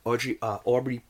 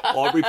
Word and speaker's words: Aubrey: You had to get past that, but Brian Tyree Henry, Aubrey Plaza. – Yes, that's how you say it, yeah. – Why does Audrey sound? We're just going to call her Audrey. No, Aubrey Plaza.